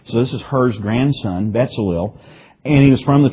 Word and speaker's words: Betzalel: His name was, it looks like Bezalel. It would actually be pronounced Betzalel. It's more like B-E-T-Z, Betzalel.